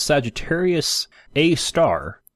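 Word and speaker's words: Sagittarius A*: This black hole is known as Sagittarius A star. That's A asterisk, but it's pronounced A star, which is really pretty cool.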